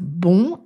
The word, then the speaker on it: bon: Cependant, la voyelle nasale « on » disparaît quand on fait la liaison en « n » avec le mot suivant. On prononce donc « bon » avec un son « o » ouvert, « bo ». On parle de dénasalisation.